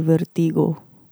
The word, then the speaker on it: vertigo: So it should be vertigo. Vertigo. Like prego. The sauce.